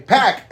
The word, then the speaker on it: PAC: How do you pronounce Neville's name? – Pack. – Pack. Like a six-pack. – Yeah. – Okay. Yeah. – I always want to say Pac because of Tupac. So like an X-Pac.